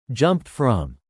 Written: jumped from

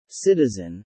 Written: citizen

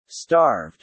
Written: starved

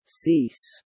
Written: cease

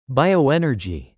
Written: bioenergy

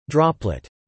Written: droplet

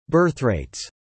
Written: birthrates

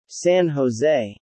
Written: San Jose

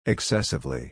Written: excessively

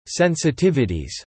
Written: sensitivities

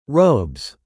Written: robes

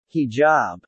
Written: hijab

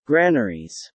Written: granaries

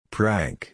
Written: prank

